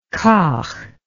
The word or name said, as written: kauch